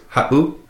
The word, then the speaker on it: ha7u: If you listen to that word ha'u, ha'u, it would be spelt H-A-7-U. If you were to take out that 7, it would be pronounced ha'u. But with the 7 in there, it makes that little glottal stop, that pause.